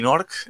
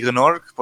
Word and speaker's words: Gnork: Está el malo, que es el señor Nasty Nork, o como dicen aquí, Nasty Gnork, porque pronuncian la G del principio, que me da bastante rabia.